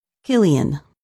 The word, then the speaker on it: Cillian: His first name is pronounced Killian.